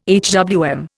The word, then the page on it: HWM: Indian